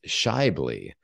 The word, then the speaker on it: Shibley: So the correct pronunciation of my last name is Shibley. And you would never, I tell people to not look at the letters. Don't look at the letters. It will just confuse you.